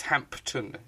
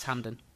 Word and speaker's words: Hampden: I always think the pronunciation of Hampden, because everyone says Hampden, but it's got a P in it. It's Hampton, isn't it? – No, it's Hampden.